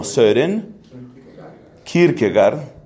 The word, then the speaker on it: Kierkegaard: Esa teoría de la apuesta la desarrolla, entre otros, un hombre llamado Søren, que se pronuncia como Søren Kierkegaard.